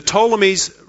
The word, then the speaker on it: Ptolemies: Pretty close. There's a silent P there, but you'll see it in a second. The Ptolemies reigned in the south and the Seleucids in the north.